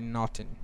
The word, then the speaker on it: Naughton: Moving on to 3LW, former 3LW member Kylie Williams accuses Natari Nottington. Did I say her name right? – I thought it was Natari Naughton. – Natari Naughton. Okay, yeah.